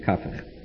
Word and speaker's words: Kafich: His Talmidim, who I know personally, tell me he pronounces it Kafich. So, even though in English it's ordinarily written K-A-P-A-A-H.